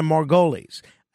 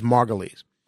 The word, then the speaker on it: Margulies: And I think she's a very good actress, but I can never get the pronunciation of her last name quite right. I think it's Margolis. So